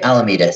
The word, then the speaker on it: Alameda: Alameda.